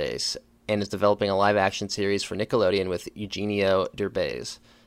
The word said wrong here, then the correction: Eugenio: I think I pronounced that correctly. – You didn't. But that's okay. – How do you pronounce that? – It's Eugenio, but it was you did a really good job.